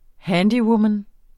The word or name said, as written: handywoman